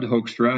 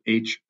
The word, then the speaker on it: Hoekstra: Yeah, probably the easiest ways are either on LinkedIn, Judd Hoekstra, and my last name is spelled Hoekstra, or I'm at Judd Hoek, JuddHoek on Twitter.